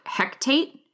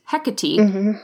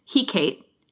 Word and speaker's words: Hecate: And it's separated from the mainland Canada by this stretch of water called Hecate Strait, which, okay, I had to look that one up to pronounce because I always thought it was like Hectate. And the internet tells me it is Hecate, unless you are in a Shakespearean play. And then it's Hecate, because sometimes you needed that pronunciation for iambic pentameter.